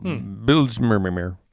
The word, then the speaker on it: Bijlmermeer: So Bijlmermeer. So I actually didn't butcher that, but it's in Amsterdam, so that's why it's so weird.